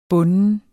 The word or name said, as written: bunden